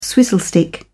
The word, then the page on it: swizzle stick: British English